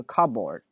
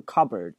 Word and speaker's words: cupboard: OK， I open the door of the cupboard，很多学生会把这个单词读成这个 cupboard，首先那个 p 是不发音的，用之前讲过爆破音，后面如果出一个辅音，你看这个 p 后面接一个辅音 b b，这个前面这个 p 就不发音了，所以它不会读成 cupboard，它读的是 cupboard，而且那个 b o a r d 直接略读成 r cupboard，不是 cupboard，我们会读成 cupboard。